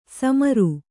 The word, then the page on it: ಸಮರು: kannada